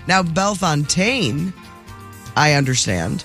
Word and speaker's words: Bellefontaine: B-E-L-L-E. – B-E-L-L-E. – F-O-N-T. – Oh. – A-I-N-E. – Well, there's how you probably should pronounce it, and then there's how St. Louis pronounces it. – St. Louis pronounces it Bellefontaine.